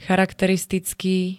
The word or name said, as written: charakteristický